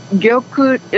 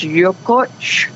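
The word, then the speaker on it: Yokuts: Yokut is what you're pronouncing. Mm-hmm. In our language, it's Yokuch. – Yokuch. – And sometimes people say Yokuch, Yokout, Yokut, you know, so it's Yokuch.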